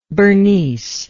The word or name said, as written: Bernice